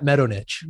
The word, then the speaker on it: Medonich: Matt Medonich.